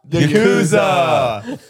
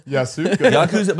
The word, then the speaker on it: Yakuza: The Yasuka? – Yeah. – Isn't it the Yasuka? Am I saying that right? – Yakuza. Yakuza.